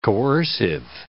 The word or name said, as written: coercive